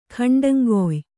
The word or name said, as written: ಖಂಡಂಗೊಯ್